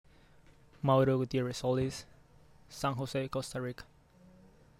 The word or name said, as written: Mauro Gutierrez-Solis